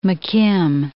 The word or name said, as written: McKim